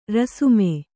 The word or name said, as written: ರಾಸ್ಮೆ